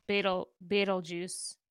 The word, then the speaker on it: Betelgeuse: Also, I looked up the pronunciation, and apparently it is just Betelgeuse is how it's supposed to be. – Betelgeuse? – Betelgeuse.